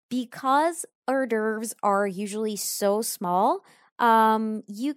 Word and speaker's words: hors d'oeuvres: Now, this is a tricky word to pronounce. It's a French word, and it's pronounced hors d'oeuvres. So the H is silent in this case.